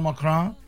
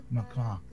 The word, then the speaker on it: Macron: El caso es que Shakira hizo un llamado al presidente de Francia, Emmanuel Macron. Lo pronuncié bien, ¿no? Sí. Estoy estudiando francés ahora. Sí. Emmanuel Macron. Macron. No, tienes que pronunciarlo bien. Si vaya a hablar de un francés y vaya a pronunciar su nombre, tiene que ser bien pronunciado. Emmanuel Macron. Macron. Macron. Con así como Tremblé. Emmanuel Macron.